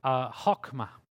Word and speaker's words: chokmah: In ancient Hebrew, the word for wisdom is actually an ancient word in biblical Hebrew, which is a word that is pronounced chokmah. And that's a word that I can even encourage you to just say right at home, chokmah. And chokmah is the biblical Hebrew word for wisdom.